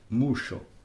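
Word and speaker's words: muŝo: M-U S-O, S ordinaire, alors que Musso, M-U-S, accent circonflexe O, S, chapeau O, pour prononcer CH, le son CH, Musso.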